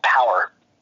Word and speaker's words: Powur: Power, P-O-W-U-R is how it's spelled.